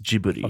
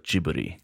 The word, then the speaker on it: Ghibli: In Japanese, the word is pronounced Ghibli. – Okay. – I can't say it very well because I can't say the Japanese like sound that's like kind of like L but kind of like okay. R. But like to my ear and to my pronunciation, when Japanese people say it and the people who founded the studio say it, it sounds like Ghibli.